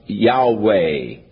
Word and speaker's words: Yahweh: So the general consensus of opinion is that the name was to be pronounced Yahweh. But somewhere along the line, the, the pronunciation of uh, Jehovah came along, or Jehovah, and it has become more popular, but Yahweh is probably the correct pronunciation though we do not know for sure.